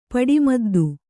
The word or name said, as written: ಪಡಿಮದ್ದು